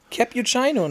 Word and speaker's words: Cappuccino: Aber ich finde es auch gut, dass du, das, dass du diese, diese original italienische Aussprache beherrschst, dieses Cappuccino. – Cappuccino, ne? Cap ich, Cappuccino. das ist. – Ja, ich war lang genug in Paris, um zu wissen, wie der Italiener Cappuccino richtig ausspricht.